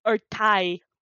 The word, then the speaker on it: Urtai: So it's like, try and do the glottal stop. Urtai. – Urtai.